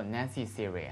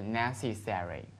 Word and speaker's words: necessary: And 还有一个词必须的，我们说是有人说 necessary 哈，但是其实是 necessary 哈，necessary 这是美国人比较通常的读法。